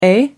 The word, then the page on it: eh: American English